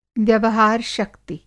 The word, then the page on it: व्यवहार-शक्ति: Hindi